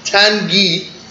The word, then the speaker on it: Tanguy: We got Tanguy, from what I've heard how he pronounces his first name, even though it's spelled Tanguy.